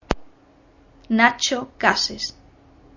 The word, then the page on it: Nacho Cases: Spanish